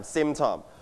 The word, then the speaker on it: symptom: Now, symptom. All right, can you listen to the audio twice? The first time. – Symptom. – One more time. – Symptom. – Your turn. Symptom. Symptom. นักเรียนปลายทางอย่าลืมออกเสียงตามคุณครูและเพื่อนๆต้นทางด้วยนะครับ Everybody, one more time. Symptom. You stress on the first syllable.